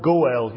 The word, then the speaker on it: goel: The Hebrew word is the word goel. You would spell it in English G-O-E-L.